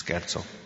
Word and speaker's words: scherzo: Did I say it right? – Yes. Scherzo.